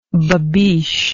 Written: babiche

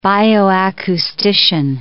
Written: bioacoustician

